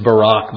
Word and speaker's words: Barak: It's funny that Hebrews 11:32 says time would fail to tell of Gideon and Barak. The correct pronunciation is Barak, but I know some of you twitch when I pronounce it that way.